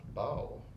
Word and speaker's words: bayou: Do you use the word for, I think it's pronounced bow. – Bow, mm-hmm. – B-A-Y-O-U.